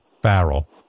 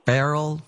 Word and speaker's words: Farrell: I don't know what was going on there, but Ralph was hanging with Terry Farrell. Do you say her name? – Farrell. – Farrell, yeah, Terry Farrell.